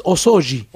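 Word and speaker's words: osouji (お掃除): El osouji se hace, ¿no? ¿Lo digo bien? Porque mi hijo, que sabe japonés, me va corrigiendo. – En realidad es osouji.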